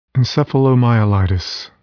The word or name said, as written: encephalomyelitis